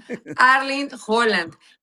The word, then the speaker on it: Erling Haaland: Lo pronuncias perfecto. Erling Haaland. Y nosotros le decimos Erling Haaland, ¿no? Haaland. A los cuates. Pero bueno, es Erling Haaland.